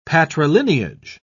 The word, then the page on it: patrilineage: British English